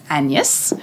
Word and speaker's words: Agnes: So it's the story of Shakespeare's wife, and Lou tells me the correct pronunciation is Agnes, who we know these days as Anne Hathaway, and the loss of one of their twins.